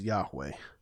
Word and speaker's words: Yahweh: The popular interpretation nowadays is Yahweh. And Yahweh is because of the Yod Heh Vav Heh, which is the letters that it makes up the name, the divine name. And Yahweh, to my understanding, is the way you pronounce it in Hebrew without the full vowel set.